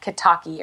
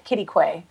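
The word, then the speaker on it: Quitaque: It's in a little town called Kittaque, which is, I'll spell it because you can never find it by the way it sounds. It's Q-U-I-T-A-Q-U-E. And so it looks like Kitaki or something, but it's like there's a sign in the town that says you pronounce it Kittaque or Kittaque.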